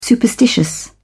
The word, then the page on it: superstitious: British English